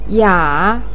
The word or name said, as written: ya3